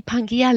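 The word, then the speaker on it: Pangaea: Und zwar ist das die Pangea Live-Versicherung. Die, die schreibt sich so mit AEA e am Ende, wird aber EA ausgesprochen. Genau.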